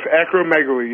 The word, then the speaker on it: acromegaly: Now, what you've done with this, and I think it's a phenomenal idea, you've created the acromegaly. Did I say it right? Acromegaly? – Very close. Acromegaly, yes. – Oh, acromegaly. Okay, you've created the acromegaly community, and that's a .com.